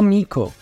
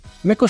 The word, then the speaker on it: Meco: Hijo de padres de ascendencia italiana, se le conoció desde pequeño con el sobrenombre de Meco, no Mico, como suelen pronunciarlo los estadounidenses, y esto fue algo que incluso él mencionó durante una entrevista en la que señaló que casi todos solían pronunciar mal su nombre.